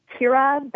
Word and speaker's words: Kyra: It's Kyra Batte. That's K-Y-R-A, B as in boy, A-T-T-E .wix.